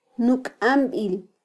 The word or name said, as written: nukambil